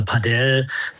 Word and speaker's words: Padel: Da scheiden sich ja so ein bisschen die Geister. Padel, Paddel, viele reden noch von Paddel-Tennis.